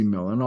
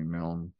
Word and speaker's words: Milne: And the Scots say Millen. I'm okay with being called Robert Lindsay Millen, although I do like Milne best.